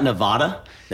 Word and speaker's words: Nevada: You left out Nevada. They can come. – Nevada, thank you. Very good on the pronunciation, by the way.